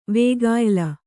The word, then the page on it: ವೇಗಾಯ್ಲ: vēgāyla